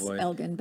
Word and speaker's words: Elgin: And I know it has should have a soft G. – Is it Elgin? G. I'm sorry. – No. It's just Austin mispronounces everything. It's a soft G because it's followed, G followed by I. It should be J. But we call it Elgin.